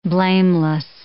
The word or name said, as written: blameless